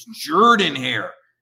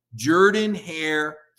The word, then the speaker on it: Jordan-Hare: And one day, finally, the SID pulled me aside and said, look, if you're going to cover Auburn, you got to say the stadium name right. It's Jordan-Hare. I said, but it's spelled with an O. Trust me, Jordan-Hare for Shug Jordan, Mike.